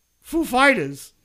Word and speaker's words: Foo Fighters: Well, how do I pronounce it? And Grohl's like, it's Foo Fighters.